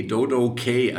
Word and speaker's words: Dodokay: Und mein Nachname Kuhn, K. -Punkt, mhm. auf Englisch ausgesprochen heißt ja K, Dodo K. Also es war tatsächlich mal okay. Englisch gemeint.